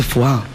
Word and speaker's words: foie: Digo, papá, se dice foie, no se dice foyer.